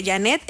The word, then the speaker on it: Janette: Janet es JANETTE.